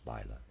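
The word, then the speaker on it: Laila: Again, I'm pronouncing the word spelled L-A-I-L-A. I'm pronouncing that Lila, end of reader's aside.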